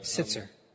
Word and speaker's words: Sittser: Und es ist von einem Mann, Jerry Sitzler. – Sitzer. – Jerry Sitzer hat das Buch geschrieben. – And it's spelled S-I-T-T-S-E-R. – S-I-T-T-S-E-R.